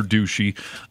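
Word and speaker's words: Doocy: So Fox News White House correspondent Peter Ducey.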